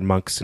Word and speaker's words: Munck: And I do want to note that Dr. Monk's name is spelled M U N C K, though it is a homonym as well for monk, like a monk.